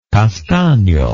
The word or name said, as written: castagno